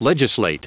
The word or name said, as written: legislate